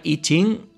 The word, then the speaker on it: I Ching: como I Ching, pero se pronuncia I Ching y lo hemos visto también en muchas veces, si lo buscamos en internet, escrito como I Ching.